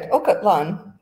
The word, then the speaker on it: Ocotlan: Ocotlan. Actually, you're doing pretty good. Ocotlan. So yeah, you're doing pretty good. Yes, we went. We took a tour. We booked a tour to go to Ocotlan.